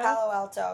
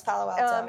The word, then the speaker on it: Palo Alto: This guy, this fire chief in, I don't know how to pronounce that, Palo Alto, California? – Palo Alto. It's Palo Alto.